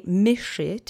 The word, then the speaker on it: myszy: So let's say myszy together three times. The accent is on the first syllable.